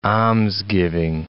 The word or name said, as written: almsgiving